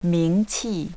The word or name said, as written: mingqi